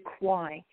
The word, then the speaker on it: Quy: And my name is spelled L-A, capital R-A-E, and the last name is Q-U-Y, LaRae Quy.